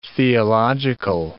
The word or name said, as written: theological